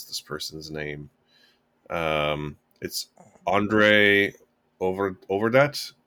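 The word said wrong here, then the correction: Øvredal: I think. Overdal? – Yes, perfect.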